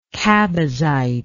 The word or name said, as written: chabazite